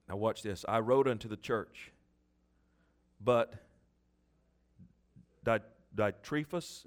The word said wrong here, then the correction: Diotrephes: how do you say that? How is it, Homer? Diotrephes. Diotrephes, who loveth to be or to have the preeminence.